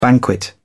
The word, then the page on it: banquet: British English